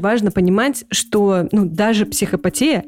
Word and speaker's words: психопатия: Вообще, Гриша, если честно, я только что погуглила, и правильное ударение – это психопатия. Простите, ради бога, мы исправимся прямо сейчас. Психопатия и социопатия.